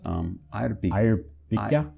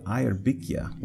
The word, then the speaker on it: Eyrbyggja: And I was asking him about it, and he pronounced it Eyrbyggja with more of a K sound in there.